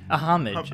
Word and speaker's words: homage: I mean, at some point, we're going to have to do a show about parodies or homage. Is that how you say it? Homage? – A homage. – A homage, yeah.